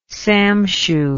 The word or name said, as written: samshu